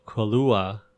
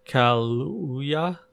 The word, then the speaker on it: Kaluuya: That was a clip from the trailer for Nope, the new Jordan Peele film starring Daniel Kaluuya. That's not how you say his name. Kaluuya.